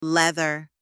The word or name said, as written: leather